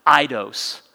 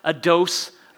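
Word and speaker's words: aidos: And in your notes, it doesn't look like it would be pronounced that way, but I love how you're supposed to pronounce this word Christ without idos. Christ without a dose of me.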